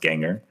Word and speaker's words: Gengahr: That's GENGAHR. I believe they're pronounced Ganger.